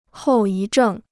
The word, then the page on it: 后遗症: hòu yí zhèng